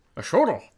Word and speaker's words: axolotl: A shortle. – Yeah, so the X, I think, is a sh sound.